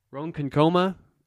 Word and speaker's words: Ronkonkoma: New York, New York City, Oakdale, Ronkonkoma. Oh, God, I butchered that. Ronkonkoma, Brooklyn, Peekskill, the Bronx, Chappaqua.